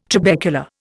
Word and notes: trabecula: Indian

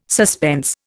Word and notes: suspense: Indian

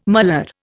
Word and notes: malar: Indian

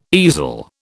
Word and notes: easel: British English

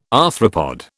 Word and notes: arthropod: British English

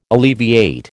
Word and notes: alleviate: American English

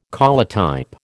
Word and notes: collotype: American English

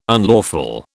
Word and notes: unlawful: British English

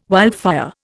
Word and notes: wildfire: Indian

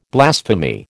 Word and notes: blasphemy: American English

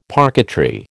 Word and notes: parquetry: American English

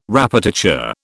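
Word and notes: répétiteur: British English

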